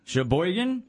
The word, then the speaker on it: Sheboygan: I thought she had it pretty close, but I was going to say Sheboygan. – She said Sheboygan. – Right. Sheboygan? – Yes.